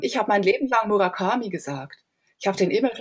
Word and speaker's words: Murakami: Und dort hat man mir dann gesagt, dass man eben Murakami sagt, dass alle das falsch aussprechen.